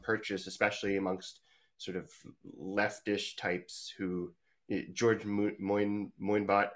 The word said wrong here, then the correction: Monbiot: how do you say his name? – George Monbiot.